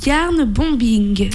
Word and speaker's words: yarn bombing: Dans les activités originales aussi, j'ai noté du yarn bombing.